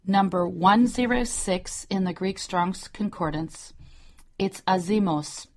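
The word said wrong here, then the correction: azumos (ἄζυμος): azimos, it's really azumos, it's pronounced azumos.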